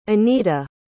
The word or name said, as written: Anita